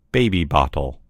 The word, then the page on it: baby bottle: American English